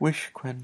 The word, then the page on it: wishkwen: Lwaitel